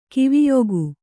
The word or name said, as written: ಕಿವಿಯೊಗು